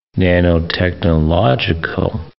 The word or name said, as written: nanotechnological